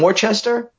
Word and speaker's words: Worcester: But I just tell them it's as easy as what our mayor used to say. It's like W-O-O-S-T-A, Worcester.